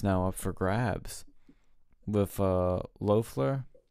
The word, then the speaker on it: Loeffler: Loeffler, yeah.